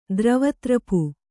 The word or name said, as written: ದ್ರವತ್ರಪು